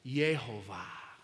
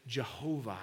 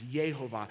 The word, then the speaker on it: Yehovah: And that word Lord, in all caps, is this name for God, Yehovah. Sometimes we pronounce it Jehovah. It's Yehovah. It's the Lord of heaven and earth.